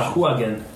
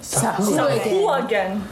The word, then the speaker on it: Sahuagin: Sagwins. – Sagwin. That's a pretty good pronunciation. – Sahuagin. – Sahuagin.